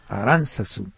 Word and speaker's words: Aránzazu: Entonces la palabra Aránzazu es esdrújula y por lo tanto se acentúa en la antepenúltima sílaba. Gracias, pregunta Rosa. – Pues en la segunda de las A's, Aránzazu.